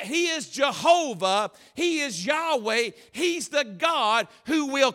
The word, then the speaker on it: Yahweh: name Yahweh, or the, it was sometimes pronounced Jehovah. And this is the greatest name probably of given to God in the Old Testament.